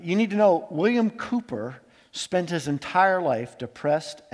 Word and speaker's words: Cowper: It's actually spelled Cowper, but it's pronounced Cooper.